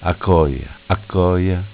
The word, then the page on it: Acoia: Dialetto